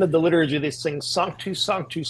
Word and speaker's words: Sanctus: Like in Orthodox churches, they sing, you know, it's properly pronounced Sanctus, but I didn't know that at the age of 18. So I've been saying Sanctus and convincing millions of people to mispronounce a word for the last 20 years.